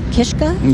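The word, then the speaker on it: Kiszka: I want to make sure I get his name right. Sam Kishka. – You got it.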